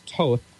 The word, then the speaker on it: Thoth: It's spelled T-H-O-T-H. You don't pronounce it Thoth. It's Toth.